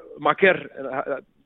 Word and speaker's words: Maker: Thon Maker, or Maker, that's how we have to pronounce it, by the way.